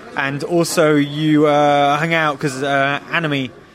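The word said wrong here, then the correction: Aimee: Is that how you pronounce her name? Anime of the Interrupters. – Oh, Amy.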